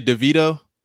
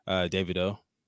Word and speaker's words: Davido: And then right, but no, you was about to say DeVito. Nah, but that's how people say it. I would expect you to say DeVito if you don't know. – But how do you pronounce it? – Davido.